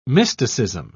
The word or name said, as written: mysticism